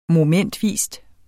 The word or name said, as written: MOMENTVIS